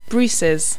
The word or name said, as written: bruces